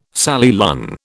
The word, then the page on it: Sally Lunn: British English